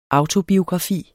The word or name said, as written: autobiografi